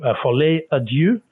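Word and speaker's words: folie à deux: But if not, I better just say follet à Dieu is what we understand it is pronounced as, meaning the madness of two.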